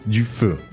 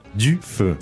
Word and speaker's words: du feu: Du feu. Sounds a little bit difficult to pronounce these two words. Du feu. – Du feu. It is a little difficult, especially if you're an English speaker.